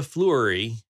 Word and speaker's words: Fleury: It is the naked woman that Nova Fleury, that's spelled F-L-E-U-R-Y. I'm assuming that's how you pronounce her last name, Fleury. – Fleur. It's like Fleur Delacour.